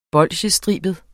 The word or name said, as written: bolsjestribet